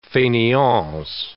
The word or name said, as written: faineants